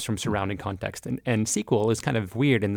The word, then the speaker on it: SQL: Where do you fall on pronouncing SQL, SQL? – SQL. – SQL. All right. – Sure. – Okay. We'll go with SQL here. The official pronunciation of this podcast is SQL.